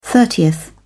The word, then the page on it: thirtieth: British English